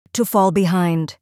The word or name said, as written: to fall behind